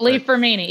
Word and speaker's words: Li-Fraumeni: Li-Fraumeni, yeah.